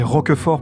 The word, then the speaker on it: Roquefort: Alors, je vais le prononcer Roquefort pour faire plaisir à tous nos auditeurs aveyronnais. C'est un fromage qui, effectivement, n'a pas beaucoup changé.